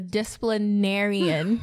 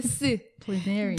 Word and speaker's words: disciplinarian: My dad was, I kind of practiced this word for like a while, the disciplinarian. – Still saying it wrong, babe. – Disciplinarian. – Disciplinarian. – Disciplinarian. – There you go.